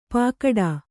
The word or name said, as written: ಪಾಕಡಾ